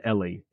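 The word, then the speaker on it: Eli: The character, Ellie is the proper pronunciation? Ellie? – I've always said Ellie.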